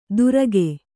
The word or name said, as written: ದುರಗೆ